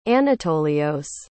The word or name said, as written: Anatolios